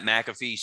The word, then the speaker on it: McAfee: McAfee. Jesus. I pronounced it like the virus software. Robert, who do you have as the best commentator? – Yeah, I went with Pat McAfee.